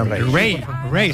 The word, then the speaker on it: Raye: Ray, se escribe Raye, Raye, con Raye, y a Raye, Raye.